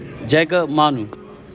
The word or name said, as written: JACOB MANU